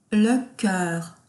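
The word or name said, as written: le cœur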